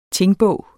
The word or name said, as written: tingbog